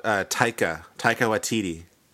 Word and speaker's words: Taika Waititi: I'm going to get his name wrong, but is it Taika Waititi? Is that? – Oh, Taika Waititi. – Taika Waititi, the director of the new Thor movie.